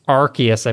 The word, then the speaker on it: Arceus: Arceus, I believe, yeah.